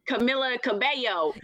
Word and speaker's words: Camila Cabello: Camila Cabello. – Yes. – Is that how you say it? – Yes.